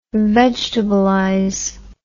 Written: vegetablize

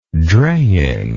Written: draying

